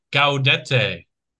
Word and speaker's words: Gaudete: I'm Googling it. Guadete pronunciation. Ready? Here we go. I'm even picking the guy who speaks French. Go! Gaudete.